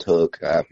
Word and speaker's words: Hoek: My last name being Hoek, it's spelled Hoek, but it's pronounced Hoek. My ancestors didn't know how to spell, I guess.